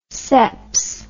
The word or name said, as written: seps